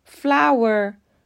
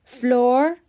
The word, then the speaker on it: flour: لغت سوم F L O U R به معنای آرد تلفظ درست flower فلور غلطه این لغت دقیقا مثل F-L-O-W-E-R flower به معنای گل تلفظ میشه.